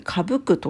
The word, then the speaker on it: 傾く: 傾くと書いて、かぶくと読むんですね。